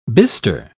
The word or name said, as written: bister